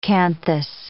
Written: canthus

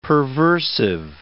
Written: perversive

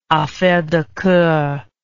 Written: affaire de coeur

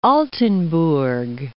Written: Altenburg

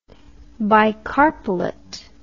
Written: bicarpellate

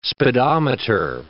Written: speedometer

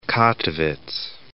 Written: Kattowitz

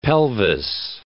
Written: pelvis

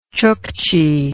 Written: chuckchi

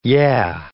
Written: yeah

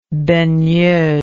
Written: baigneuse

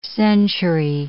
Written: century

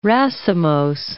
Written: racemose

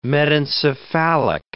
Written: metencephalic

